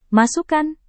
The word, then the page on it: masukan: Indonesian